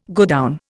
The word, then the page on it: godown: Indian